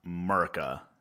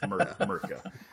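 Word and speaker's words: Mirka: It's not pronounced Merca. – Merca. – It's Merca. But I started the dining room table.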